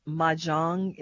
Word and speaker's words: mahjong: who would sit for weeks on end playing mahjong. Is that how you pronounce that? – Yeah, that's right.